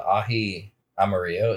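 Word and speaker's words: ají amarillo: But yeah, the ahi amarillo is how you pronounce it.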